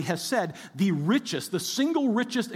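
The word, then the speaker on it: hesed (חסד): And the reason I want you to pay attention to that word is because the Hebrew term behind it is a word we would pronounce it, our best effort to say it would be hesed.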